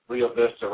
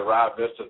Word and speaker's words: Rio Vista: Rio Vista, Rio Vista, depending on where you live, which is how you say it.